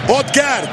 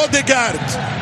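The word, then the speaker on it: Ødegaard: خطورتهم الحقيقة نوسا سورلوث هالاند، الختام في الهجمة بإيرلينج، واللي يبدأ ويصنع هو طبعا أودغارد أو أوديغارد، سميه كما شئت وانطقها كما أحببت، فهم لا ينطقون اسمي عصام.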